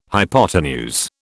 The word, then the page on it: hypotenuse: British English